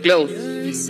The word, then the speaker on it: Clothes: Underneath Your Clothes. ¿Y yo qué dije? – Eh, y lo dijiste medio como Clot.